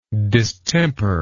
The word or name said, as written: distemperedness